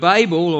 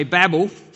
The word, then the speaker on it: Babel: The word Babel, or as the Americans say, Babel, and perhaps they say it more right.